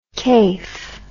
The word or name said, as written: kayf